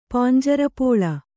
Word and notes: ಪಾಂಜರಪೋಳ: Kannada

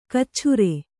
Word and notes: ಕಚ್ಛುರೆ: kcchure

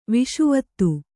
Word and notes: ವಿಷುವತ್ತು: kannada

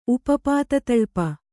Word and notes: ಉಪಪಾತತಳ್ಪ: Kannada

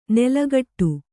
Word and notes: ನೆಲಗಟ್ಟು: kannada

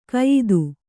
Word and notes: ಕಯಿದು: kayidu

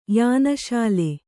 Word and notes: ಯಾನಶಾಲೆ: yāna śale

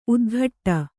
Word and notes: ಉತ್ಕಟ: utkaṭa

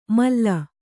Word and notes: ಮಾಲು: mālu

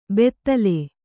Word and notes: ಬೆತ್ತಲೆ: bettale

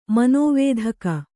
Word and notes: ಮನೋವೇಧಕ: manōvēdhaka

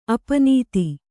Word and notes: ಅಪ್ಪುನಿಧಿ: appunidhi

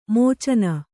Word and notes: ಮೋಚನ: mōcana

